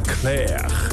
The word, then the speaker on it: Clerc: Nou ja, het is in ieder geval een grote hit uit 1974, zoals ik al zei, van Julien Clerc. En dan spreek je die laatste C ook weer niet uit.